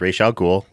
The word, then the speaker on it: Ra's al Ghul: First of all, it's Ra's al Ghul. We've already been over this.